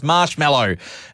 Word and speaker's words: Marshmello: Michello. That's not how you pronounce his name. It's Marshmello.